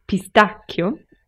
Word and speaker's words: pistacchio: So Italians actually say pistacchio. It's very, I think the spelling is exactly the same. The pronunciation is very similar, but instead of a sh, we get the hard k sound, pistacchio.